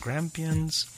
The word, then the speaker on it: Grampians: Let's go to Australia, Grampians, and November 2nd in Grampians National Park.